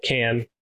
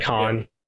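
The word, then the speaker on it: Kann: So a commonly mispronounced name is Alec Can. People like to say Alec Khan, right? – Yeah. – It's Can.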